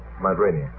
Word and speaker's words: Rainier: How do you pronounce that in America? Mount Rainier. Good.